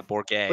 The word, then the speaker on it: Bourget: Bourget? – Bourget? Right? Come on. – Yeah, Bourget. – Like Gerald. Bourget. – Listen, man. I'm not French, man. – It's just like Gerald.